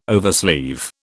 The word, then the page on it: oversleeve: British English